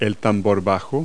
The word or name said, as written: bass drum